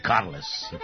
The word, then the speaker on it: Carles: Ali zato puć, puć, puć, puć demona zovemo i Carlos i Carles. – Katalonski lider zove se Carles Puigdemont, što je najpribližnije pravilima katalonskog izgovora. A u jezičko raspetljavanje krećemo od imena. Izvorno piše se Carles i izgovara Carles.